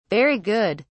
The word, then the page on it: very good: British English